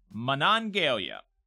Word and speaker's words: Monongalia: Uh, Jared, uh, corrects my pronunciation on a West Virginia town, Monongalia. Monongalia.